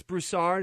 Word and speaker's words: Broussard: Let me just play a quick clip from a radio program where Chris Broussard, is that how I say his name? Yeah. Chris Broussard was on the radio program after he spoke on the news clip that we're talking about.